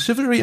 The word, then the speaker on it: chivalry: Und daher kommt sozusagen wahrscheinlich diese Chivalry und deswegen wird es auch so ausgesprochen, wie es ausgesprochen wird.